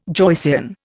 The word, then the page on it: Joycean: Indian